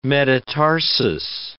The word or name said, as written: metatarsus